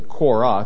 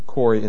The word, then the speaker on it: Korah: Now, often we'll just say Korah, because that's the Old Testament pronunciation of it. They just changed it to Kori in the Greek, and then it gets translated back to the English that way.